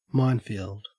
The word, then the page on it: minefield: Australian English